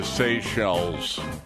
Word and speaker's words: Seychelles: S-E-Y-C-H-E-L-L-E-S. – Oh, that's named for the Seychelles Islands.